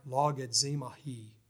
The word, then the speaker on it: logizomai: And it's something like logizomai is what the word is pronounced. Something like that.